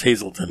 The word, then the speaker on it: Hazleton: It's spelled Hazleton, but it's pronounced Hazleton.